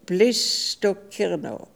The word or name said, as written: Blissstokirno